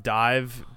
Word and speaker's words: DIIV: Uh, Dive, but it's spelled D I I V.